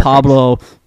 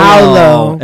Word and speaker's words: Paolo: Pablo name wrong. It's Paulo. Paolo. It's Paulo Ben Churro, and he is a monster on the court.